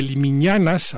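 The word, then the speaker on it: Limiñanas: Escucharemos Qué Calor, de Limiñañas, ahora sí lo dije bien, acompañado aquí este dueto de Laurent Garnier, los primeros franceses que suenan aquí en La Lengua.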